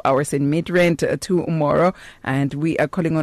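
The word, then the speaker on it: Midrand: Mid-rent.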